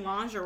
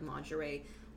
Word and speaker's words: lingerie: Like, they probably had to be like, honey, that's not how you say it. – It, like, lingerie. – Lingerie.